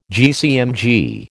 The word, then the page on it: GCMG: American English